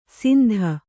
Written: ಸಿಂದ

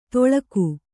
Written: ತೊಳಗು

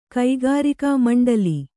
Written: ಕಕ್ಕರಿಕೆ

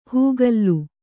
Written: ಹೂಗಲ್ಲು

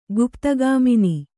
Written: ಗುಪ್ತಗಾಮಿನಿ

